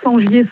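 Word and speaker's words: Sengier: Sur mon nom, Sangier Sandrine. Et il y a tout... – Sangier, ça s'écrit comment ? – Alors, S-E-N.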